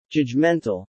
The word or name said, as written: judgmental